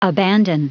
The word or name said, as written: abandon